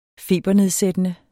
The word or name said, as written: febernedsættende